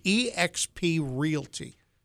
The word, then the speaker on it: eXp Realty: Lee Roach is with us, first time in the studio from eXp Realty. Did I say that right? – You said it right.